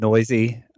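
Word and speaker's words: Neuse: By the way, Sheldon Noisy, it's not spelled phonetically.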